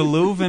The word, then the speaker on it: Louvre: Little known fact is the Louvre. Am I pronouncing that correctly? – Yeah, it sounds good.